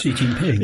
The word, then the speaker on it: Xi Jinping: and we have it's Xi Jinping, is that how you say it? I'm bad at this. – Xi Jinping, yeah.